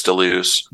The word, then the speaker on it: Deleuze: Do you pronounce the guy's last name Deleuze? – Yeah, I believe it's Deleuze.